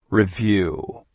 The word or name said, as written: Review